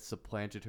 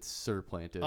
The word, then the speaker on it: supplanted: When she came back from school, Jesse had supplanted her as the funny girl at the lunch table. – Wait, so it is a word? – Supplanted. You said surplanted.